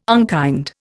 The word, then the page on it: unkind: Indian